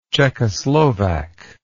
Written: czechoslovak

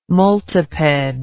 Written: multiped

